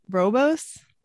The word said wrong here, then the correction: Rooibos: Rooibos, yeah.